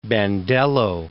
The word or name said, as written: Bandello